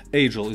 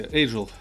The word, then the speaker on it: Agile: Agile, извините, Agile или Agile. Сегодня меня поправили, сказали, что Agile правильно говорить.